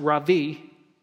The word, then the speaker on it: rabbi: Now, the Aramaic word rabbi, it's pronounced ravi, and the Greek word for rabbi is the word mentor.